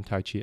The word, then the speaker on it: Tai Chi: As you know by now, the correct pronunciation is Tai Chi, but since the martial art was introduced and popularized in the West a good number of years ago, the older romanization system rendered it as Tai Chi, and that's unlikely to change.